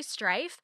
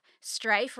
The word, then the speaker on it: Strife: My name is Kelly Strife. Strife rhymes with wife, and if you've seen it in print, you know why I keep reminding people how to pronounce it. Strife rhymes with wife.